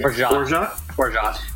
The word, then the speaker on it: orgeat: Is that how you pronounce it? – Orgeat. Yep.